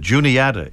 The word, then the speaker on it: Juniata: And by the way, we uh, checked on the pronunciation to confirm that Juniata is how they uh, say the name of that college.